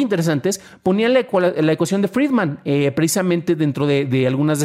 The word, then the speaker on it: Friedman: Y la cuestión es que Friedman se pronuncia muy similarmente a Freeman o hombre libre.